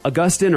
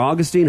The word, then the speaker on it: Augustine: Issues between Augustine or Augustine, however you want to pronounce it, however scholarly you are, I guess.